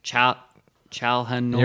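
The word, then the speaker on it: Çalhanoğlu: Kalinoglu. – Chalhanoglu. – You're right. C is J in Turkish.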